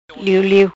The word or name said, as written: Liu Liu